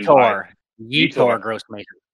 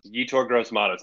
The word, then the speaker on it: Yetur Gross-Matos: But I think kind of for a more deeper dive pick, I like, and I hope I'm getting his name right, but it's Yetur Gross-Matos from Penn State, who was taken Yetur Gross-Matos. Yetur Gross-Matos. – Yetur Gross-Matos. Thank you so much.